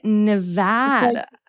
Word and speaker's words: Nevada: Okay, so drag the A. Nevada. – It's like Nevada.